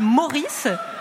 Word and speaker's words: Maurice: Alors, jeudi, sur l'île Meurice. – Non. Ouais. Bah non, vous prononcez mal. C'est l'île Maurice.